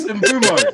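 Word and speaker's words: Mbeumo: No, he said Mbembu. – It's Mbumo. – Mbumo. Sorry.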